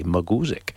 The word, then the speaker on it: Mugusic: We heard the Nighttown Muguzic, kind of like music, but it's spelled M-U-G-U-S-I-C, Muguzic.